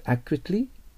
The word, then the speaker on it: accurately: Can you pronounce this word accurately? と言えばいいと思います。そして、単語の、左側の単語の, ね、表示を見て発音記号を見てください。 A のとこが強いでしょ。 Accurately となります。